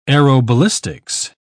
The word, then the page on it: aeroballistics: British English